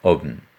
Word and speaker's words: haben: Retournons la forme infinitive qui se prononce « haben ».